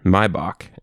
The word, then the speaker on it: Maibock: I know how to pronounce it because Mr. Greg Dalrymple, shout out, giving him that little plug, sold these to me. And Maibock is what it's called.